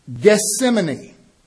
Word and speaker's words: Gethsemane: This Olive Garden had a functional name, Gethsemane. That is the Greek pronunciation of the Hebrew word for oil press.